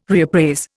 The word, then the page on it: reappraise: Indian